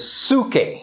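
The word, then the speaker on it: suke (ψυχή): But the word that we translate, the two words that we translate as life in English is one, the phonetic pronunciation is suke.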